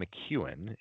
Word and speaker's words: McKeon: One guy who's looked, I would say, who surprised me a little bit is Sean McEwen. It's spelled McKeon, but he's the Michigan tight end. And Harbaugh does not throw to his tight ends. If you watch Sean McEwen tape, it's block, block, block, block, block, flare pass, block, block, block, block, block.